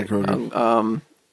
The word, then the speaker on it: Grogu: Yeah, Grogu.